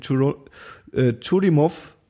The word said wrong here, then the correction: Churyumov: nee, Churi, Churyumov Gerasimenko.